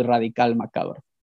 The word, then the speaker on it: Radical Macabro: It's Spanish, so Radical Macabro.